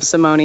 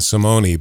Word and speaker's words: Simone: All right, and and uh, and Simone, that is the way to pronounce her name because it's kind of like Casa Grande. I got half the people saying Simone, and then the other half saying Simone, but she prefers Simone.